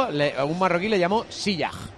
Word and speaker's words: Ziyech: No se pronuncia en la E, no es Ziyech. – No es mal, pero ¿cómo es bien? – Ziyech.